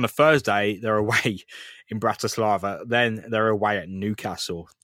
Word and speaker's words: Bratislava: Bratislava.